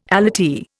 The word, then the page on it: allottee: Indian